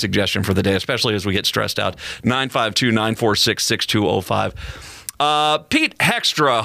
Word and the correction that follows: Hoekstra: Hoekstra, I think is how you pronounce Pete Hoekstra.